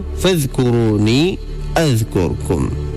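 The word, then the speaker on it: فاذكروني أذكركم: اب ایک مرتبہ پھر وہ آیت پڑھو فذکرونی اذکرکم, ایک مرتبہ پھر آہستہ سے فذکرونی اذکرکم. ماشاءاللہ صحیح تلفظ ہے آپ لوگوں کا بھی,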